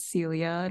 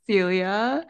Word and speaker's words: Celia: So I guess to just say my name again, my name is Celia, not Cecilia or Celia Wheelia. My name is Celia.